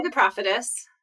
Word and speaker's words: The Prophetess: So since the last time we met, or the last time we podcasted together, The Prophetess is now, I'm putting the accent on the wrong syllable, aren't I? How do you say it? – I would say The Prophetess.